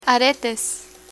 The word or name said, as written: aretes